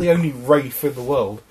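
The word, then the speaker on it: Ralph: Also, my name's Rafe, actually.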